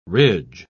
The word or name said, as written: ridge